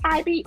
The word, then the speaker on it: Hybe: I don't, no disrespect, but I think it's pronounced Hybe or H-Y-B-E.